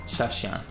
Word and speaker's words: Shashian: Okay, and so just to confirm, am I pronouncing your name right? Tom Shashian? – Shashian.